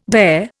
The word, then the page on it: dare: Indian